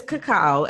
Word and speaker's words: cacao: So it is cacao.